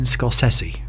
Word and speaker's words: Scorsese: Martin Scorsese. – Okay. – It's not Scorsese, as everyone seems to think. It is Scorsese.